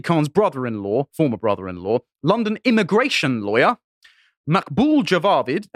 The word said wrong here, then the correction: Javed: I can't pronounce this, sorry, Javed, sorry, was married to Sadiq Khan's sister until 2011.